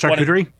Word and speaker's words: charcuterie: but it's Charcuterie? – Yeah, there you go.